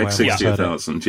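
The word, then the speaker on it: Sharp X68000: I don't know how to pronounce that. 68,000. – Yeah, that's the X68,000. Yeah. And I had a look at this, and I think this is really, really, really cool looking.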